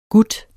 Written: GUTTERNES

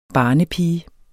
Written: BARNEPIGES

